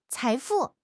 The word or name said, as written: 财富